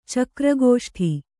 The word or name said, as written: ಚಕ್ರಗೋಷ್ಠಿ